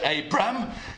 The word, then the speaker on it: Abram: And you notice I was trying really hard to pronounce it, Abram, because in the first half, probably, of all the Bible chapters about him, he's called Abram.